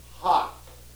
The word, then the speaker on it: hough: H-O-U-G-H. Now, how would you pronounce H-O-U-G-H? Ho? You? Believe it or not, it's pronounced hock, as though it were H-O-C-K. It's an old Scottish word, and it's in the King James Bible, and that's the way it's pronounced. It's the strangest thing. It's not pronounced anything like it looks.